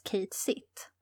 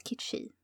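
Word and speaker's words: Cait Sith: där finns det en figur som heter, det stavas, ser ut som det ska uttalas Kate Sitt, uttalas Kitchi.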